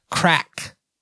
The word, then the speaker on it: craic: First of all, being crack. C-R-A-I-C, pronounced crack.